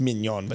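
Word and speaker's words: Maignan: Oh, it's I think it's pronounced Mignon, but it's Mike Mignon who plays for France.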